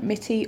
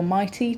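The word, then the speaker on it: Mitie: So facilities management giant Mitty, or Mighty, depending on your pronunciation, it's a scone scone kind of deal, has set a net zero carbon target for 2025.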